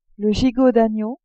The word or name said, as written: gigot d'agneau